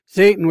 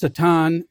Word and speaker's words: Satan: Now remember, Satan, which is actually pronounced Satan, Hebrew, and it always is accompanied with the article the, the Satan.